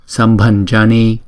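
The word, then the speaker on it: Sambhañjani: Chữ N có dấu ngã chúng ta đọc là nhờ. Sambanjani, Sambanjani.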